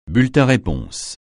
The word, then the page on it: bulletin-réponse: francais